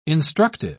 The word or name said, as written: instructive